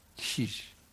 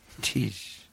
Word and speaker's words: Tish: And I want to say now that my name is not TJ. It's pronounced Tish. Tish. Like if a Middle Eastern man was to tease someone, he'd go, Atish. Atish.